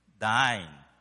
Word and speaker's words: thine: Thine. Okay. It rhymes with mine. It's not mine. It's thine.